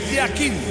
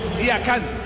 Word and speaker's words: Yakin: Uno es pronunciación suiza y la otra pronunciación turca. En el suizo se pronuncia así: Murat Yakin. En turco, Yakan. Cambia la última parte de su apellido.